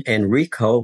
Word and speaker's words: Enrico: Enrico, which is Italian for Henry, E-N-R-I-C-O, and then Naulati. Last name is spelled G-N-A-U-L-A-T-I.